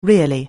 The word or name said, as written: really